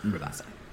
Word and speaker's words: Rabassa: Yeah, Rabassa.